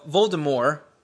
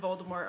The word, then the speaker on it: Voldemort: the T is silent in Voldemort, according to J.K. Rowling. She responded with, but I'm pretty sure I'm the only person who pronounces it that way.